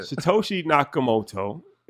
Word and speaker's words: Satoshi Nakamoto: Satoshi Nakamoto.